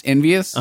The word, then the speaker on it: Envy Us: well, it's pronounced Envious, but it's spelled Envy Us.